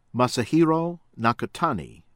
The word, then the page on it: Masahiro Nakatani: American English